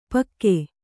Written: ಪಾಗು

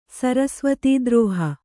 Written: ಸರಸ್ವತೀದ್ರೋಹ

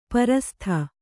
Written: ಪ್ರಸ್ತ

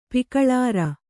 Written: ಪಿಕಳಾರ

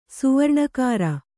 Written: ಸುವರ್ಣಕಾರ

